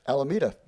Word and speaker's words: Alameda: Alameda.